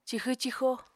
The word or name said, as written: cixw-cixo